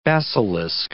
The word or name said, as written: basilisk